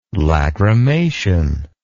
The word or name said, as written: lachrymation